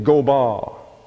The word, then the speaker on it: gobah: The next word you see is proud, and that is the word G-E-E-H, G-E. The next word you see is haughtiness. And the word for haughtiness is gobah, G-O-B-A-H. The fourth is pride. The word there is gaava. That's spelled G-A-A-W-A, gaava.